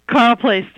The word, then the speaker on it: Carle Place: I understand your hometown is this pronounced Carle Place, New York? – Carle Place, New York.